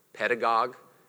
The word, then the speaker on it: pedagogue: The Greek word for guides, and I don't know who's fluent in Greek in here, but it's pedagogue. That's how I pronounce it.